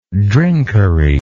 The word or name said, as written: drinkery